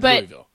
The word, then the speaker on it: Louisville: Plus, he said this S is silent. Didn't I say? I swear I said Louisville. – But they do, as a Southerner, they say Louisville.